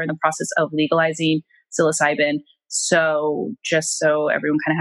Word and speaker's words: psilocybin: And I wanted to explore microdosing, and I hope I'm pronouncing this correctly, psilocybin. Is that how you say it? – Yes. Yeah. – Okay, psilocybin.